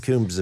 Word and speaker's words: Coombs: Yeah, it's a B in it. I think you'd say Coombs. – Coombs, sorry. – Coombs. It's kind of like almost a silent B, but not quite. Coombs. It's Coombs.